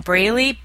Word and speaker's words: Braley: that's spelled J-O-L-Y-N-N, Braley, B-R-A-L-E-Y.